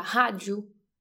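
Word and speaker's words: rádio: O sea, no sé si por ejemplo la R si está al principio de la palabra se pronuncia como G, entonces, si una persona escucha Haju no va a pensar en radio.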